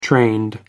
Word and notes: trained: American English

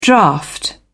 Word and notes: draught: British English

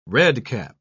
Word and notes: redcap: British English

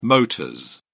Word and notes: motors: British English

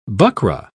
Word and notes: buckra: British English

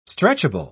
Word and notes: stretchable: British English

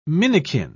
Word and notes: minikin: British English

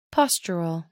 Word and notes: postural: American English

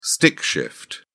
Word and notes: stick shift: British English

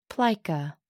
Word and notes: plica: American English

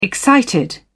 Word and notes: excited: British English

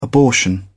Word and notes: abortion: British English

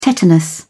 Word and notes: tetanus: British English

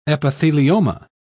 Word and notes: epithelioma: British English

